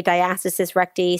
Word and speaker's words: diastasis recti: I'm going to try to say this, diastasis recti. Did I say it right? – Yeah. So there's, you know, it's tomato, tomato, but I say diastasis recti. Some people say diastasis recti.